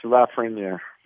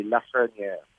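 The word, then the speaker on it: Lafrenière: Alex Lafreniere. – Lafreniere. Is this kid as good as he's being? C'est ça. En français, c'est Lafreniere.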